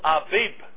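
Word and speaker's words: Aviv (אביב): That is the month Aviv, spelt A-B-I-B in English. But as Tel Aviv, the city Israel gets that name. In the scripture you will read Tel Aviv, A-B-I-B, because that's how that letter is to be pronounced in Hebrew. Not to give you a Hebrew lesson, but that's why Abram is Avram.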